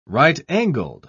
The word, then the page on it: right-angled: British English